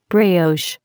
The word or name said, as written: brioche